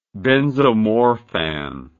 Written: benzomorphan

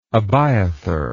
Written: Abiathar

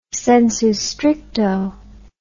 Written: sensu stricto

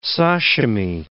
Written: sashimi

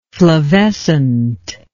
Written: flavescent

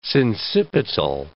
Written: sincipital

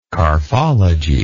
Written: carphology

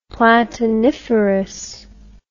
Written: platiniferous

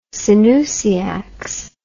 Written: synousiacs

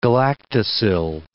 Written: galactosyl

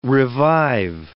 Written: revive

